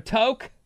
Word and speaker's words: toque: T-O-Q-U-E, I think. – Right, T-O-Q-U-E. But it's pronounced toke, like take a toke.